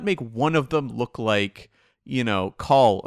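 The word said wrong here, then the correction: Kol: You're still mispronouncing his name. – I am, yes. – It's Cole. I remember having to have many off-mic conversations with you going, it's Cole. It's pronounced Cole.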